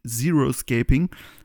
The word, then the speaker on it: Xeriscaping: Und zwar gibt es das sogenannte, im Englischen spricht man Zero-Shaping aus, das habe ich ja eben, Zero-Scaping.